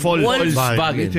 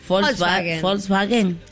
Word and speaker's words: Volkswagen: Sí, algunos dicen Volkswagen. – Que la pronunciación correcta en alemán. – Volkswagen. – Claro. – Volkswagen. – Por eso, pero nosotros acá lo decimos como podemos.